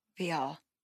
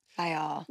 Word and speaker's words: Viall: I'm going to say Nick Viall. It's not Nick Viall. – Viall. Yeah, because he said all. Viall.